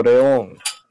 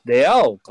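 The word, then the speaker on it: Leão: テオとレオン。レオンじゃないな。レアオか。北川さんの発音的には。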